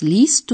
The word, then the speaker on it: liest: Et d'autre part, la voyelle du radical de lesen comme c'est le cas pour certains verbes, change au deuxième et troisième personne, le E devient IE, prononcé I. Écoutez-le encore une fois. Lesen. Was liest du?